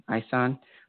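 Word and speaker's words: Esan: And the Yoruba people, that, because it's not just Nigeria, I found it was the, it's called the Isan, it's E-S-A-N, but they pronounce it differently, Isan.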